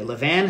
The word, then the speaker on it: levain: Mr. Frenchman, how do you pronounce it? – Levan.